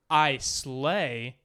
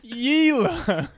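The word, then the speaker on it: Islay: But this week, we're going to be covering the regions of Isla, spelled I slay and pronounced Yila. What is happening on this? It's pronounced Isla, but the islands.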